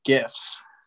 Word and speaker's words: GIF: How do you pronounce that? – They're GIFs. – GIFs with a hard G.